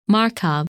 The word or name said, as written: Markab